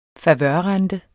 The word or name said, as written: favørrente